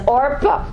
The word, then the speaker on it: Orpah: Oprah, did I say that right? No, I said it wrong. I always do this. Orpah, thank you.